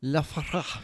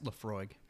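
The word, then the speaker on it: Laphroaig: It is actually pronounced Lafroig.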